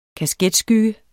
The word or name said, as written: kasketskygge